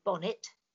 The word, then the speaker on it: Bonnet: I mean, the thing is that Charles Bonnet was Swiss, Genevan, and so I always pronounce it as he would have done.